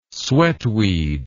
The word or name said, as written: sweatweed